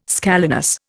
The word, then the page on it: scalenus: Indian